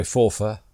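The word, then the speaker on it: Forfar: And today, we're going to continue our new, relatively new, series of podcasts featuring the Fife and Forfa Yeomanry. – We like to pronounce it properly, don't we? Forfa.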